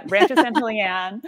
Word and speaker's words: Rancho San Julian: So could you tell everyone a little bit more about yourself, the incredible history of, is it Rancho San Julian or Julian? – Yes, that's exactly right. Yeah, you said it right the first time. Yeah, Rancho San Julian.